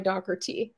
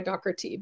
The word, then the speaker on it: Doherty: And we actually come from an origin that was O'Dochertaig, D-O-C-H-T-A-I-G-H, from Northern Ireland. So we actually go by Doherty.